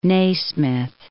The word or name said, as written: Nasmyth